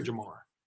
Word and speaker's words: Jahmyr: Is it Jahmyr or Jahmyr? – Jahmyr.